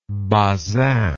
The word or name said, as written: Bazin